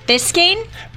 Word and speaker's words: Biscayne: Biscayne? – Biscayne. – I think it's B-I-S-C-A-Y-N-E.